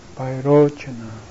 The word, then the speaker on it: Vairochana: You said the Vairochana, you said that Vairochana Buddha, you see Chanai, C-A in Sanskrit, it goes like so. In English, we put romanize this character C-H-A and pronounce Cha, Vairochana.